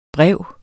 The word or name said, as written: brev